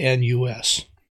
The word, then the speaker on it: Longinus: But one of the persons that I want to mention to you, his name was Longinus, and I'm not sure I'm even pronouncing it right, Longinus. It's Longinus.